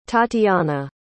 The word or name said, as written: tatiana